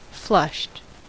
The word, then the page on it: flushed: American English